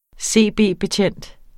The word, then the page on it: CB-betjent: ˈseˀˈbeˀbeˌtjεnˀd; seˈbeˀ-